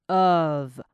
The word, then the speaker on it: of: 但你要快速念过去，你会发现它就会变成 behavior，所以前面那个 b e 就是 b b b 的音了 behavior behavior，这样就会很好听了。那看一下后面那个 of，记得后面那个 f 是 v v v 的音 of，好，这样就 OK 啦。好，最后两个小小的重点，你看到 dogs 后面那个 g 跟 s 合在一起的时候，后面那个 s 就会是 z 的音 dogs。